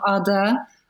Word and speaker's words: Ada: And this book taught me a lot, especially writing the character of Ada, as we would pronounce her name in Turkish.